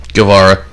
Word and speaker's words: Guevara: Guevara. – I'm going to use the Spanish pronunciation there.